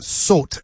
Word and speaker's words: Sowt: And the name of the album is, I believe, pronounced Sot, S-O-W-T, Sot, which means something like sound.